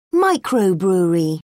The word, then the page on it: microbrewery: British English